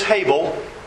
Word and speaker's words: Hebel: The Hebrew word is Hebel. In English, it's spelled H-E-B-E-L.